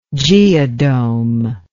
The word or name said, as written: geodome